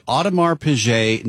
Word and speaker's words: Audemars Piguet: It's a piece from Bloomberg Global luxury editor Chris Rouser on what he calls the little black dress of watches, the Audemars Piguet Neo-Frame Jumping Hour.